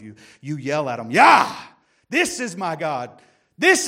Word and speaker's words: Yah: It's one of the only words that is actually in all capital in the King James Bible, and it's Y-A-H. And you pronounce it Yah or Jah. I don't think God cares either way, but it's the name of God that you cry out in the face of your enemies.